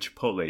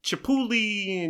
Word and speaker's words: Chipotle: Uh, so Dominic Brascia, uh, also uh, noteworthy is uh, the way he pronounced it Chipotle. He couldn't pronounce it for whatever reason, and his mind and his brain can process it, which some words, I'm that way too, but uh, he would pronounce it Chipuli and Chipatople and Chipalulu and all this stuff.